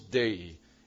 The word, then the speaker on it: dei: Three letters, two syllables, dei.